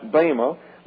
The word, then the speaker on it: Bema: It's pronounced Bema. Looks like Bama.